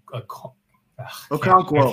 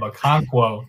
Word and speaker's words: Okonkwo: Guys like Chig Okonkwo. – Okonkwo. – I can't say his name. Okonkwo, excuse me.